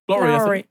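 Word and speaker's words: Abigail Lawrie: Is that how you say it? Laurie? Lowry? – I think so. Laurie.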